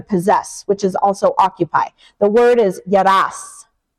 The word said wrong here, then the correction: yarash: Not I'm probably saying that wrong. Yarash. That's how yarash, not your yarash.